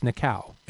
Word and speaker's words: Nikao: It's pronounced Nikao, N-I-K-A-O.